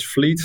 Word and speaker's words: Vliet: So my name is Pim van Vliet, pronounced as Vliet. So that's Dutch.